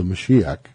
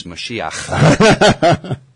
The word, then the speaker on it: Mashiach: the Mashiach, did I pronounce that properly? – No. – I didn't. How do I say it? – You got to get a little bit of phlegm. It's Mashiach.